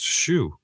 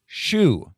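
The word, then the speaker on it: Hsu: IMDb has a pronunciation. Pronounced Shu. – Shu.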